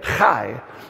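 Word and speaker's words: Chai (חי): The letter on the right is Chet. You have to say it like you're clearing your throat or something, like you got a little cold. Some of you don't have to work at this because you're already getting your fall cold. But his name is, it's Chet and Yod. And together, it's just this short little word, Chai. That's how you say it, Chai.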